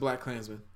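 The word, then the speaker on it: BlacKkKlansman: Black KK Klansman. I don't know how it's pronounced. KKK. – No, it's just Black Klansman. – Oh, just Black Klansman? – But then they put the KKK.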